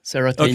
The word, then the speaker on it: Ceratanium: Okay, the two things that made you famous within IWC, and Chris told me you got to talk to Mr. Ceratanium. Did I pronounce that right? First thing. – Yes, Ceratanium, okay.